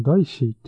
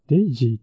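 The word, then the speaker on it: DAISY: このダイシーっていうのは何なんだろう。あ、デイジーって読むんだ。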